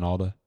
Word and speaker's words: Alda: Alan Alda. Is that how you say his name? – It's a D. – Alda? – Yeah. – Alan Alda.